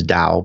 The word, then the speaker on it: Tao: And of course, the correct pronunciation is Tao, but I mean, a lot of people pronounce it as Tao, and that's perfectly fine.